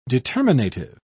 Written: determinative

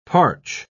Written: parch

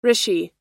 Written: rishi

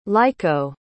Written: leiko

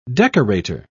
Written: decorator

